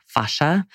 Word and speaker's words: fascia: So fascia, it's spelled F-A-S-C-I-A, fascia. Some people say fascia. How's your fascia today?